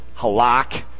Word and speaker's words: halak: The Hebrew word for walk is, well, it sounds phonetically similar. It's halak.